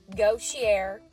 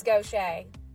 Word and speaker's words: Gautier: Number two, you can get all fancy Nancy with this one, but it's actually not pronounced Gautier or Gaultier. It's Gaucher. That's right.